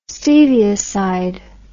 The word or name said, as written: stevioside